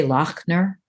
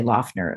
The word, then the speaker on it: Lochner: How's it pronounced? – I say Lochner. – Lochner, okay. – Because it's Scottish, but some people say Lochner. That's fine, you know.